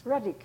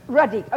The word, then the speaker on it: Radek: R-A-D-E-K. – It's Radek. – It's Polish. – Where's it come from? Oh, that's a nice name your mummy gave you. Radek.